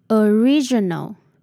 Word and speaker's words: original: 那再来，我们看到 gi，在这里 g 我们要发 g 的音，i 呢则是发 e，念作 j j。那最后的 nal 我们会一起念，al 发 o，那跟前面的 n 一起叫做 no， original original。